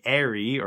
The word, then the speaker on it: aerie: Pronunciation stuff on Star Wars 7x7 again, as in an eagle's nest, an Airy, A-E-R-I-E, not as in the Abercrombie and Fitch brand or whatever it is.